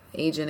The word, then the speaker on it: Agin: Agin again. It's A-G-I-N.